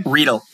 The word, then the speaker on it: Riedl: Riedl.